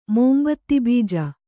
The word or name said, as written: ಮೋಂಬತ್ತಿಬೀಜ